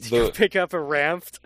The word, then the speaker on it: Ranft: How do you spell that Ranft? – R-A-N-F-T. – Oh, okay. Just how it sounds. Okay. I thought maybe there was a D in there.